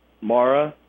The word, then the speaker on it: Marra: It's Kevin Michael Mara Jr. And it's spelled just like it sounds? – Last name is M-A-R-R-A.